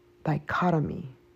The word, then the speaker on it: dichotomy: But once we become 18 years old and we have our own dicht- dichtomony, dichtomony, am I pronouncing it right? our own voice, our own willpower dichotomy.